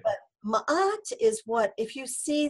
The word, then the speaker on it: Ma'at: Ma'at is spelled M-A, and there's a little apostrophe, A-T. So it's like Matt, but Ma'at, okay?